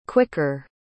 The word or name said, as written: quicker